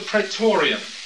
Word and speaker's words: Praetorium: Now, Pontius Pilate lived in what was called the Praetorium.